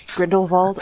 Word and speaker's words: Grindelwald: So Grindelwald, I guess is the correct pronunciation. Gr- Grindelwald? Yeah. Very, very Germanic.